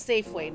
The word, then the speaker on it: Safeway: And we drove by a Safeway. And in Tagalog, it was Safiway. So I'm like, okay. And my uncle's like, no, it's Safeway, not Safiway.